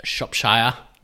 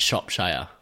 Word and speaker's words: Shropshire: Smiles every time I say it because you do not say it right. – I don't say it right. Please. – It's a Shropshire Lad in Switzerland. – Shropshire. – Shropshire is a county in the UK. There you go. There's a geography lesson for you. – Not Shropshire. – No. I mean, I'm sure you could say it like that.